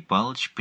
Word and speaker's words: Палыч: Второй слог произносится как «промах», ударение на последнем слоге, «ей», как в «прей» добыча, но чуть более растянуто, «Тимофей Палыч Пнин», что значит «Тимоти, сын Поля». В отчестве ударение на первом слоге, а остальные проглатываются, «Тимофей Палыч».